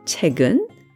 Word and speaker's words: 책은: 책, the book, followed by the topic particle 은, 책은, which is pronounced 책은.